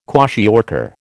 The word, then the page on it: kwashiorkor: American English